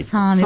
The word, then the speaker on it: Sami: Kelime olarak Sami diyenler var. – Sami. – Sami. Uzun. – Sam. İsmi Sam. Hazreti Nuh'un 3 oğlu var. Yafes, Ham, Sam. Sami.